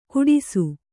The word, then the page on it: ಕೆಟ್ಟಾಸೆ: keṭṭāse